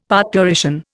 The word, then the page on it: parturition: Indian